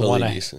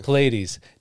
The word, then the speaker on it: Paulides: I can't say his name. His last name is the Pilates. – Pilates. David Pilates.